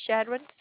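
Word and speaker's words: Chadron: Is that how you say it, Shadron? – Yep.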